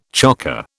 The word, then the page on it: chocker: British English